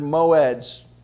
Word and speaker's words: moed: However, that word and feast is the Hebrew word moed, pronounced or spelled just like you think, M-O-E-D, moed.